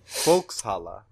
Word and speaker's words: Volkshalle: V-O-L-L-E-S. Like Volkswagen, but S-H-A-L-L-E. Like People's Hall, basically. – Volkshalle. Yeah. Whenever you see an S and an H in German, they're not pronounced together.